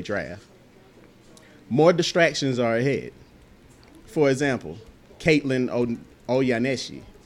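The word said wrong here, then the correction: Ohashi: I hope I pronounced that name right. Oyashi, I'm sorry. It's Caitlin Oyashi.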